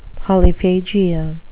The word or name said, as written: polyphagia